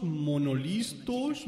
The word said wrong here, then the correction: monologuistas: monolistas, monolingüistas, lingüística, pronunciación es lo que le falta a este tío. Monologuistas. Tan difícil no es.